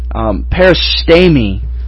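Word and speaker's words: peristemi (παρίστημι): But within the scope of this specific word, peristemi is really how you would pronounce this word to present.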